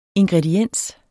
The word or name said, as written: ingrediens